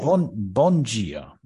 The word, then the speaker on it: Bom dia: Bom dia.